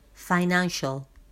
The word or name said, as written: finantial